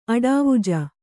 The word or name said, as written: ಅಡಾವುಜ